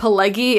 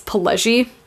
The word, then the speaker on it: Pileggi: said his name wrong in the Goodfellas episode. I think I said Pileggi. It's Pileggi.